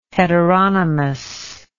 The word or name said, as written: heteronymous